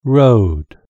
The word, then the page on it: road: British English